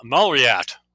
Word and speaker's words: Malriat: Sam Malriat.